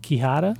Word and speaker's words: quijada: Speaking of weird instrumentation, I should bring up, Suze, the piece of percussion that you're working with there, which is, I believe, it's called a quijada? – Yeah, great pronunciation there.